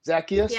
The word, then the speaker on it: Zacchaeus: Zacchaeus. Is that how you say it in English? Zacchaeus? – Yeah. – He was mafia. And yeah, that's how we say it, Zacchaeus.